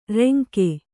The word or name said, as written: ರಂಗ